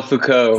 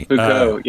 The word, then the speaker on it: Foucault: Michel Foucault. – Yeah, thank you. Sorry. – Foucault, yeah.